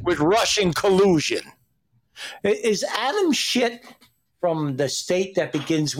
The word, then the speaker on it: Schiff: Yeah. Adam Schiff. – No, it's Schiff.